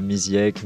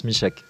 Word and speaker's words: Misiek: Donc Michek, M-I-S-I-E-K, c'est ça? – Exactement, ouais. Ouais, j'ai droit à Misiek, Misiek, j'en ai plein, mais ouais, ça se prononce Michek.